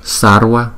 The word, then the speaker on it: Sarva: Thì chữ V đứng sau một phụ âm thì chúng ta có thể đọc là W W W Sarva Sarva. Tuy nhiên cái luật đó không phải là 100% như vậy. Cũng có nhiều vị đọc là Sarva đó. Nhưng mà đại đa số là đọc là Sarva Sarva. Tathagata Tathagata Tathagata.